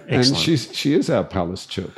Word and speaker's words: Palaszczuk: I've been told that Anastasia Palaszczuk, our premier, the pronunciation of her name is in Polish Palaszczuk.